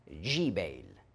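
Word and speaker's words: Jbeil: Et voilà, Tyr, une ville du Liban au sud, et Gebal, en arabe, on prononce doucement le G, Jibel.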